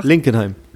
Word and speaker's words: Linkenheim: In Enkenheim, habe ich es richtig gesagt? Linkenheim. Na, Linkenheim.